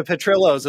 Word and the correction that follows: Portillo's: Is Petrillo's still there? – Oh, yes. Portillo's. Yes. – Portillo's. Yes, thank you.